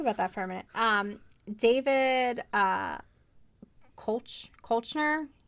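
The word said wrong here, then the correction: Koechner: he's Uncle Howie. – Keckner? – Is that how you say it? – Yeah.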